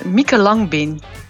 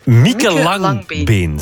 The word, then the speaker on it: Mieke Langbeen: Ik wil het vandaag hebben over de naam Mieke Langbeen. – Mieke Langbeen, zeg ik dat goed? – Ja, okay. – Ja, inderdaad.